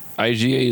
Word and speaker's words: Iga: Is it Iga? Iga? – IGA, like the supermarket.